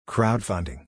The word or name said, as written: Crowdfunding